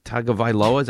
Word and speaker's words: Tagovailoa: Tagovailoa, is that how you say it? – Yes.